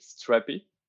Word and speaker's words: Strapi: we pronounce it strappy. – Okay. – But yeah, if you want to pronounce it strappy, yeah, it's okay. It's strappy, and it comes from bootstrap your API.